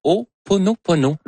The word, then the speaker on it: Ho'oponopono: Non, c'est une philosophie hawaïenne de, je vais le prononcer comme il faut, de Ho'oponopono, qui